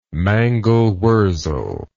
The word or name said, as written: mangelwurzel